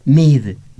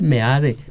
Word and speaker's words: Meade: Y en castellano también, meade no se dice mid, se dice meade.